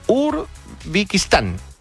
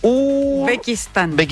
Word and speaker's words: Uzbekistán: Y hoy Cristiano Ronaldo con Uzbekistán. No, lo dije mal el país, ¿no? – Uzbekistán. – Uzbekistán.